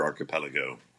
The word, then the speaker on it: archipelago: And there's 1,000-plus islands here in the Alexander Archipelago. – Is that how you pronounce it, archipelago? – Yes, sir.